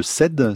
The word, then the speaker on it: CED: En septembre prochain aura lieu le congrès européen sur les TCA, qui s'appelle le CED, c'est comme ça qu'on le prononce.